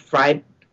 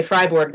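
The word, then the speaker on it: Fribourg: Freiburg. That's how they pronounce it. If you say Freiburg, then you're going to end up in Germany, right.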